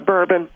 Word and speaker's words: bourbon: Yeah, I've got a boobin. – It's called a bourbon. – It's called bourbon, Dooley, please. Please don't mispronounce it again.